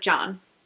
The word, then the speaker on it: Can: his last name is Y-A-M-A-N. – Wait a minute. What were you spelling the first name? – His first name is C-A-N. And it's pronounced like John.